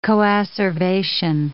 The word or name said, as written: coacervation